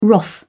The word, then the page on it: wrath: British English